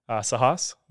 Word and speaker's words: Sahas: This is episode 25, and we have a special guest on today, Sahas.